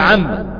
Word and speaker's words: عم: لا، هي لا تنطق الا موصولة، عم يتساءلون بتشديد في الحرف الثاني، نعم مما عم اما.